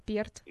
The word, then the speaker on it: Перт: Я был в Австралии где-то 2 года назад, но Маргарет Рива, Перф такой город есть, если я правильно его произношу. – Можно его произносить как Перт.